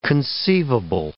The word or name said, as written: conceivable